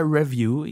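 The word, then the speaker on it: Revue: Review oder Review? Review auf jeden Fall, die Betonung auf dem View.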